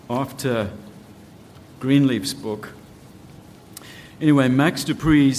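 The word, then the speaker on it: De Pree: One of the other authors who has written books on this type of leadership, the style of leadership, is someone called Max Dupree. We would probably pronounce it Max Dupree in South Africa, but anyway. Max, P-R-E-E.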